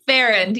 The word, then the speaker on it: Farrand: Farrand, yes.